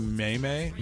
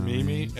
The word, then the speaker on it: meme: Someone called it a maymay. – Maymay? I don't know. – A meme. And I used to think it was a maymay. I had no idea. – Did I pronounce it correctly? – Meme? You said meme. You said it right.